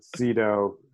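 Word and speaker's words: Zeeetow: And then if you want to hit me up on Instagram, it's just Zito. So it's Z and then three E's and then T-O-W. So my cousin actually stole our real name. So I guess she can have it, but yeah, so Zito is spelled very weird, but I'm always happy to talk bikes with anybody via any format because that's my life.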